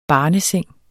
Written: barneseng

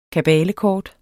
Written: kabalekort

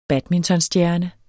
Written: badmintonstjerne

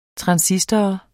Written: transistor

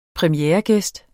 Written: premieregæst

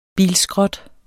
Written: bilskrot

